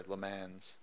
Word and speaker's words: Le Mans: That was the first time that that Porsche won Le Mans. Sorry, Le Mans. – Mm-hmm. – Porsche won Le Mans. – Porsche won Le Mans. That's right. – That's the correct pronunciation, exactly.